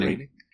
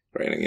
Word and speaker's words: Groening: How do you say his it's name? Groening. – Groening, yeah. – It is. Yeah.